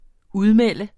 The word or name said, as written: udmelde